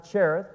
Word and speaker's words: Cherith: A lot of people pronounce it Cherith. Cherith probably more proper, but either are all right.